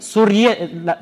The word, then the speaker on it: سریه: سوریه, سوریه تلفظ درستشه من بررسی کردم از سریه میگن سوریه یعنی کنیز